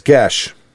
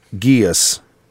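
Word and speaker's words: geis: I'm not sure if I've ever said it out loud, but in my head I've always pronounced it geas. But that rhymes with, says it rhymes with flesh, mesh, and thresh, among other words. So it's gesh.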